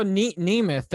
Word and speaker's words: Nemeth: Okay, so I saw Nemeth. That's how you say it.